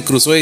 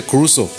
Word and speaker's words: Crusoe: De, hecho, me había concentrado en que no digas Crusoe, porque todos dicen Crusoe y se pronuncia Cruzo.